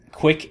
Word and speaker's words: QUIC: Um, and also, uh, I'd like to say that this is this is probably not hipster because QUIC is pronounceable and it uses vowels, although it's spelled like quick, as in fast, uh, but without the K.